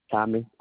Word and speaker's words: Tommy: Tommy, T-O-M-M-Y, Lee, L-E-E, Golightly, G-O-L-I-G-H-T-L-Y.